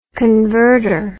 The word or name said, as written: convertor